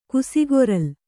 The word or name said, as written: ಕುಸಿಗೊರಲಿ